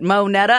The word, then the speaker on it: Mo Netta: So Jordan's like, you know, like Mo Better, but Mo Netta.